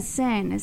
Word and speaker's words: Cinn: Yeah, Christiana Sin, C-I-N-N, you know, because I wanted it to be C-C.